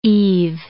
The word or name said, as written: eave